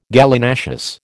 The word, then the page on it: Gallinaceous: American English